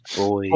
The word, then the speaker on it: buoys: It's pronounced boys.